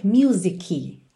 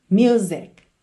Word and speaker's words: music: E eu acho que nem precisava, mas eu vou chamar a sua atenção para nunca dizer music. Ok? É muito comum, como a palavra música em inglês é uma palavra que muitas pessoas usam no dia a dia, é frequente esse erro de pronúncia music. Lembra que essa letra C é uma consoante oclusiva, ela vem encerrando o som da palavra music, music, ok?